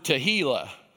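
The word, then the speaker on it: tehillah: And with great deference to my brothers and sisters from the South, they pronounce this word tahilah. And every one of them makes a joke about, just remember it. Tahilah sounds like tequila.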